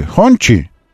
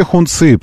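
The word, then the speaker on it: Hongqi: И на мой вопрос, скажите аутентичное произношение на русском, они мне сказали «хончи» это произносится. А вы можете хунцы продолжать говорить.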